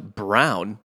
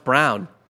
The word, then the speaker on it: Braun: This guy, he might get a cup of coffee in the G League, but Christian, this is annoying, pronounced Brown. – It looks like Braun. – Yeah, pronounced Brown.